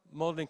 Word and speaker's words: Magdalen: Lewis basically went to Magdalen College. Is that how you pronounce it? Magdalen College. Thank you very much.